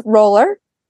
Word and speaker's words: Rohler: So my name is Liz Rohler.